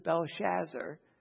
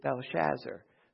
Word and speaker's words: Belshazzar: And in chapter 5, we have the Feast of Belshazzar. You notice the difference. Daniel's pagan name is Belteshazzar. This king is Belshazzar. So almost the same, but you notice the extra syllable.